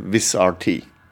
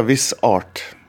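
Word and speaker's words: Vizrt: Men uttalelsen Teknisk sett så sier en jo egentlig 'Wizard Tea'. Men det er jo noen som sier 'viss art'. Det syns jeg jo egentlig høres eh, eh, bedre ut. da.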